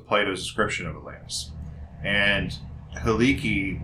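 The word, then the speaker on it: Helike: Well, it's spelled he like, but it's pronounced Haliki.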